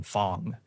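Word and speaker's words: F.A.N.G: Now, their big reveal here was the final playable character in Fong. It's spelled Fang, but they pronounce it weird.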